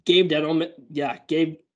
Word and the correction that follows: Gettleman: I can't say his name. Jeez. – Dave Gettleman. – Dave Gettleman. English is hard, guys.